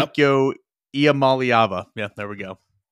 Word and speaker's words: Iamaleava: Iamaliava.